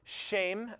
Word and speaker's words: Shem: Shem, or Shame, as it would have been pronounced,